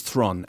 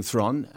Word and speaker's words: Theron: And ever since then, I've been saying Theron, and I was told yesterday I got it wrong. – No. – You did a great job. That was it. – But they said, no, it rhymes with heron.